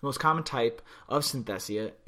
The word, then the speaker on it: synesthesia: Synesthesia. Synesthesia? No, not even close. Synesthesia. Synesthesia. There we go. Got it. Nailed that.